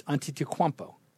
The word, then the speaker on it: Antetokounmpo: I've been led to say it's Antetokounmpo.